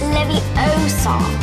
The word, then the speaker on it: Leviosa: You're saying it wrong. – It's Leviosa.